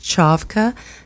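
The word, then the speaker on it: Čavka: It looks like Kavka, but there's an accent on the C, so it's pronounced Chavka.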